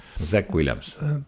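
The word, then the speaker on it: Zach Williams: see on see Shah Williams. – Zack. Nagu Zachariah, nagu Zakarias. – Zachariah, okei, okay. No võt näed. – Zack Williams.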